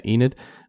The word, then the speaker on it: Enid: Near Enid. – Yeah. – Is it how you say it? Yeah, Enid.